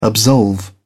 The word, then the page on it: absolve: British English